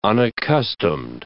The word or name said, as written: unaccustomed